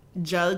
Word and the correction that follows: Gal: Is that anti-Semitic, pronouncing her name that way? – Was it? Well, her name's Gal, but her last name is Gadot, so...